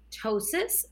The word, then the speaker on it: ptosis: The other thing I see quite a bit is something called ptosis, and that's spelled with a P as in Paul, T-O-S-I-S, the P is silent.